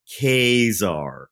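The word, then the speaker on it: Ka-Zar: And so he's like, okay, just to get this out of the way, it's pronounced Kazar.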